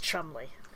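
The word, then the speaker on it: Cholmondeley: It's spelled like Cholomondely and IGH. And it's pronounced Chumley.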